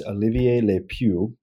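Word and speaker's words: Olivier Le Peuch: I always butcher his name, but I think it's Olivier Le Peu. Is that right? – Yep, that's right. – So kind of like Pepe Le Peu.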